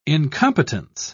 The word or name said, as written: incompetence